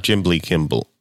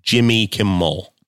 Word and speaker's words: Jimmy Kimmel: It's Jimbly Kimble. – No, you've pronounced that incorrectly, Andy. It's not Jimbly Kimble. It's Jimmy Kimmel.